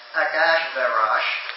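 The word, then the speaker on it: Akashverosh (אחשורוש): Because the Persian king's names, like Ahasuerus, pronounced Akashverosh. I love the Persian names, particularly.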